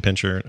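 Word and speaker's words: Pinscher: Is it pincher or pincer? How do you say it? – Doberman pincher. – I always say pincher, but I don't know.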